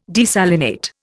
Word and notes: desalinate: Indian

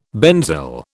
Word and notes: benzyl: British English

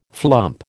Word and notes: flump: American English

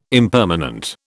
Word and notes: impermanent: British English

